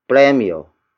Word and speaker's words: premyo: And that is premio. Premio. Premio. Premio is spelled as P-R-E-M-Y-O.